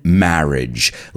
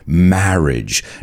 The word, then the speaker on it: marriage: According to Wikipedia, a wedding is a ceremony where two people are united in marriage. Let's pronounce that word together. Marriage. No se pronuncia age, sino idge. Marriage.